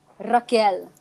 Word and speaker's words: Raquel: It's roll your R's when you say her name, dude. – Raquel.